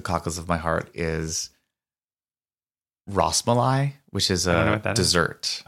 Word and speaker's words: Rasmalai: Rasmalai. – Yeah. – That's how you pronounce it.